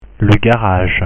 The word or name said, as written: le garage